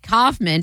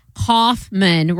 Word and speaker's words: Kaufman: And Kaufman, pronounced Kaufman, rhyming with cough plus man.